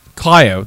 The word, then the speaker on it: Clio: Don't right, but in case you don't know, they have a pronunciation guide that says pronounce Clio, K-L-E-E, Clio.